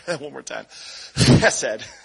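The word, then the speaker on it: Chesed (חסד): The word for steadfast love in scripture is chesed. Chesed with the ch in there.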